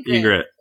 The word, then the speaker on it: Ygritte: Y'all can't say her name right. What's up with nope. – that? It's Ygritte. Like the bird.